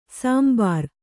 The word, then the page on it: ಶಾಂಬರ: kannada